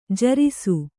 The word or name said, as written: ಜೇರೈಸು